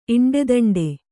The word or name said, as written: ಇಂಡೆದಂಡೆ